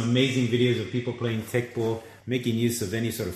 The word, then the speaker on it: Teqball: I think everyone out there would now be interested in tech ball. And tech ball is spelled TEQBALL, is that correct? – TEQBALL.